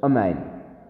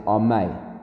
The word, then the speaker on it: amen: So, chatufa is Rashi explains it's like saying amen, not amen, not with the comets, but amen with a shva, like the two dots at the two. So it's amen. So you're saying it in a very rushed way, and you're not saying it properly. Velo amen chatufa. And you mustn't have a cut-off amen, like saying amay, without saying amen. So you haven't said the nun properly.